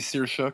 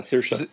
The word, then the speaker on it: Saoirse: Et d'ailleurs, il y a aussi Saoirse Ronan, je ne sais pas trop comment on prononce son nom. C'est Saoirse qu'il faut dire, en fait. – Ah, Saoirse.